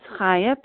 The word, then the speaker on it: Chaya: This is Kaya P. in Colorado. I spell my name C-H-A-Y-A, but it's pronounced like you're saying hello. Thank you so much for leading the meeting, and thank you everybody for sharing, and welcome to anyone who's new.